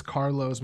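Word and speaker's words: Kharloz: Carlos,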